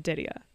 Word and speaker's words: Daria: I'm like, why is, you know, so my legal name is spelled D-A-R-I-A. And, you know, I've always been called Didia by my family. I've always been Didia by my family. But being raised in Canada, I was called Daria.